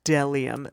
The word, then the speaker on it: Bdellium: It's pronounced Delium. The B is silent.